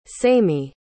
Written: samie

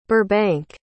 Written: burhbank

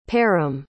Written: param

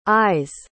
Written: i's

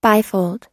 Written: bifold

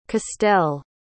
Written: castel

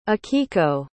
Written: akiko

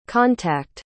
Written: contact

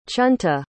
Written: chunta

